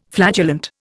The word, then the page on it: flatulent: Indian